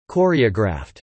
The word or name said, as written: choreographed